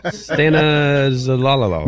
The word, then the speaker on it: Stanozolol: Stanozolol. Or, you got a better pronunciation on that? – That sounds about right. – Stanozolol? – Stanozolol. – Maybe this is why I don't use steroids.